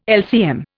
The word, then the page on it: LCM: Indian